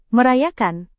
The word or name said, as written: merayakan